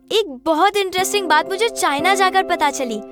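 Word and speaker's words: Qin: अगर कोई Chinese word या नाम में इंग्लिश का क्यू लेटर आता है तो उसे च प्रोनाउंस करते हैं। जैसे क्यू आई को कहते हैं चाय, क्यू आई एन को कहते हैं चाइन। सो दैट्स वाई क्विन डायनेस्टी से नाम पड़ा चाइना।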